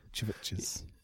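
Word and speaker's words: CHVRCHES: Yeah, which makes me think you should just pronounce it the way it's spelled. Churches.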